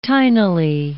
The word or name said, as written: tinily